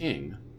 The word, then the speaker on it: Ng: Well, I know that NG, I've had friends that's their surname, and it's pronounced Ing.